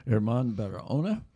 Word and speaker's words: Herman Barahona: And we are glad today to be welcoming in Herman Barahona.